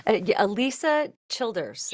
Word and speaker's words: Alisa Childers: Alisa Childers.